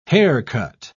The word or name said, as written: haircut